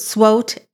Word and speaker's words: SWOT: It might be, some people might pronounce it SWOT, S-W-O-T, but what exactly is it and what does that stand for?